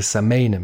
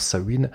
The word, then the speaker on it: Samhain: Bon voilà, faut-il présenter Glenn Danzig, donc, qui a joué dans Misfits, dans Samhain, enfin ça s'écrit Samhain, mais ça se prononce Samhain,